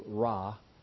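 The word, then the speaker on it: ra (רע): It's the word, I'll pronounce it for you, ra.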